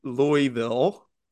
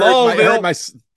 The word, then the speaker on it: Louisville: I felt at a show in Louisville at Monic Brewing. – Please fucking say it right when you're in my fucking when you're in my presence. Please say it right.